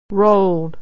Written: rolled

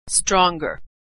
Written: stronger

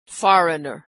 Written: foreigner

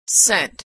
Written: sent

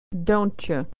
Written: doncha

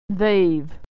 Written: they've

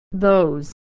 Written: those

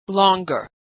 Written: longer

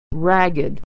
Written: ragged

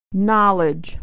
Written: knowledge

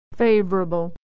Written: favorable

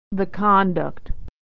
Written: the conduct

